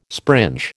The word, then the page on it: springe: American English